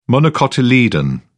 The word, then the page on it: monocotyledon: British English